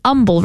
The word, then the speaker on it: Humble: Goes to St. Mary Magdalene, and it's an humble, right? Humble. There's no H in humble, right? – Well, there is an H, but it is silent.